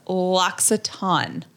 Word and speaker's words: L'Occitane: It's L'Occitane.